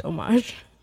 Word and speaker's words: homage: It's pronounced homage. It's a French word. – It's homage like a mother. – It is homage. – I've been saying homage my whole life. – Well, I mean, you can still say homage. People know what you're talking about, but the word is pronounced homage.